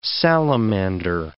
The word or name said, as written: salamander